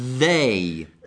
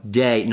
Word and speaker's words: they: They. – They. – Riprova. They. – They. – No, day, no, day è giorno.